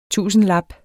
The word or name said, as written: tusindlap